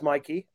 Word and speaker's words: Mikey: Or my personal favorite, a pet peeve of mine, is when I introduce myself as Mikey, it's spelled M-I-K-E-Y, and everyone's like, is Mickey around?